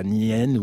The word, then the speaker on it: Nien: Je ne sais pas comment on prononce, Nien, N-I-E-N, ah oui, Nien.